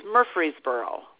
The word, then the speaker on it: Murfreesboro: It's Murfreesboro.